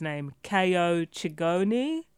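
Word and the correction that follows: Chingonyi: right? – Chiongi. – Right, thank you.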